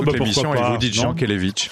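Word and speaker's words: Jankélévitch: Et question prononciation, tu préfères te taire à tout jamais ou dire une fois pour toutes à Simon que ça se prononce Jean Kelevitch ? Et c'est vrai, c'est... Yann Kelevitch.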